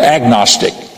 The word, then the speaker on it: agnostic: Now, most of us are familiar with the word agnostic. The word agnostic is an A in front of the G, so that the G is pronounced when you put the A in front of it.